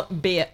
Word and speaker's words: Biya: Paul Beer. – Oh, yeah. I just realized what it sounds like. – Paul Beer. It's not spelled like that. – It's B-I-Y-A.